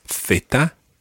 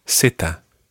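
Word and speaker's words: zeta: In Spain, it's theta. – Theta. – And in Latin America, seta.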